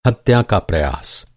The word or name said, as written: हत्या